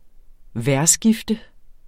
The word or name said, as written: vejrskifte